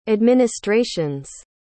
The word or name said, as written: administration's